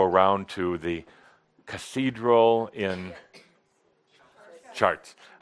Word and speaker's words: Chartres: Yeah, we're going to go to, I wish Alain was here to pronounce it, Chartres? – Chartres. – Oh, yeah. – Chartres.